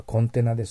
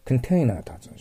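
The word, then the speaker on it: container: これは覚えるのはコンテナです。含んでいる、入れてある容器のことをコンテナと言います。ただ発音に気をつけないといけない。コンテナーと発音します。コンテナー。コンテナじゃない。コンテナーです。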